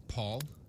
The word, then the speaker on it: palled: But the thought palled. – Ooh, palled, P-A-L-L-E-D. – To lose strength or effectiveness. – That's the one. – Yep. – Paul is the pronunciation? – Yep.